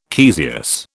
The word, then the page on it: caesious: British English